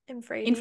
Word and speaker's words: infradian: You said it's so it's in how do you say that? Infraradian, infradian. – Infradian, yeah.